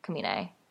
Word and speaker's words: Komine: And my Snapchat is DUPPYLOVE, or just my full name, which my last name is KOMINE, Kamine, in case you didn't know how to pronounce it like many yes.